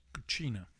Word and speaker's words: cucina: Cucina. – What? – It's a hard C.